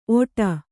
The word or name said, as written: ಒಟ